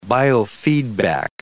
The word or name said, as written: biofeedback